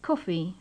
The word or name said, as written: coffee